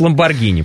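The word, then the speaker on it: Lamborghini: Значит, «Г». Значит, «Ламборгини», правильно говорить.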